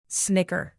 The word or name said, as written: snicker